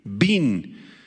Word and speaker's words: bin: La palabra entendido o entendimiento proviene del hebreo bin, B grande y N con acento en la I, bin, y describe una capacidad sobresaliente para percibir, comprender, entender, discernir, tener una gran capacidad de introspección y una gran inteligencia.